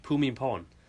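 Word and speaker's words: Bhumibol: Number two is actually his name is actually pronounced Pumipon.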